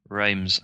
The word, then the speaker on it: Reims: But yeah, there's another dude as well in France, plays for Reims, I think you say it, the one that's spelled R-E-I-M-S, called Krykowiak, who's a Polish defensive midfielder.